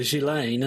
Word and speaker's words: Ghislaine: But everyone just the only time I've heard people say her name out loud, it's just simply Ghislaine, as though she were Elaine with a G on the front.